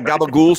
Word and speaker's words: gabagool: So, you know the Sopranos, of course, and you're familiar with the Italian deli meat, gabagool? – I don't eat meat, so I damn sure don't know what gabagool is. So I've never had a gabagool sandwich.